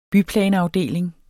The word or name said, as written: byplanafdeling